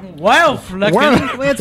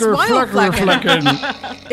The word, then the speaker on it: Wildflecken: I was an army brat living in West Germany in a place called Wildflecken. It was a Wildflecken. – That's what it's pronounced. Wildflecken.